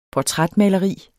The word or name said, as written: portrætmaleri